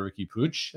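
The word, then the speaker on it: Riqui Puig: Now, Arsenal have been previously linked with the move for the Barcelona midfielder, Ricky Puig. That is how you pronounce his name.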